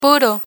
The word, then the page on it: puro: Spanish